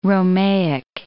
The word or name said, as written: Romaic